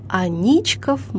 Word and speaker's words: Аничков: Ударение именно на «и», чей батальон во времена Петра I дислоцировался за Фонтанкой в так называемой Аничковой слободе.